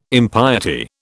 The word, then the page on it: impiety: British English